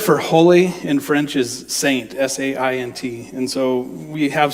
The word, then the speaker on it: saint: Well, in French, saint, saint, saint. And of course, you don't say it like that. It's saint.